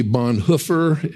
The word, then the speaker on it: Bonhoeffer: I indicated that I had only recently learned that the correct proper pronunciation should be Bonhoeffer.